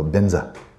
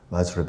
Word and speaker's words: Benza: So they go from Va. You don't have Va in Tibetan. You have Ba. And then you put this into Tibetan pronunciation style, and it becomes Be. But they don't have J and Vajra. They don't have that either. So what the hell? Za. So Benza. Vajra becomes Benza.